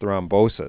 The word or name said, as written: thrombosis